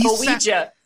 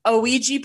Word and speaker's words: Ouija: He picks up a Ouija board and he pronounces that word O-U-E-J-A, basically. Like he Ouija. – He sounds out every letter and it's very strange.